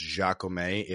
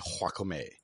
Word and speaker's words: Jacomet: I'm informed that his name is not pronounced Jacomet. It's actually Jacomet, like a nice bowl of guacamole.